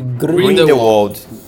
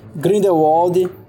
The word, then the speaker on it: Grindelwald: Como é que fala o nome desse cara? Grindelwald, Grindelwald... – Grindelwald. – Grindelwald. É isso mesmo. Grindelwald. eu, eu queria Grindelwaldo.